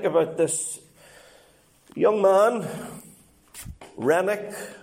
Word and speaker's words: Renwick: It's spelt R-E-N-W-I-C-K, but it's pronounced Rennick, so there's a silent W in there, all right? Whenever I first looked at it, I thought it was Renwick, but apparently the pronunciation is Rennick, okay?